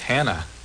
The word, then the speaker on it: Tanna: How do you spell your name? – T-A-N-N-A. – No Y in there. – Nah. – So that's... – It's like... – it's Tana, right?